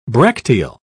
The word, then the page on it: bracteal: British English